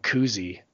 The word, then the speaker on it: Kuhse: I mean, St. Mary's has a guy named Kuzi, so I can't... it's not spelled like Bob Kuzi. This guy's name is spelled K-U-H-S-E.